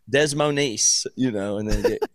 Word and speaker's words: Des Moines: I'm pretty sure it's Des Moines. I don't think it's a. Is there? There's never a silent S.